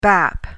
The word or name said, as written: bap